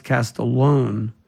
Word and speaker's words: Castiglione: His last name is pronounced Castellone. But it looks like Castiglione.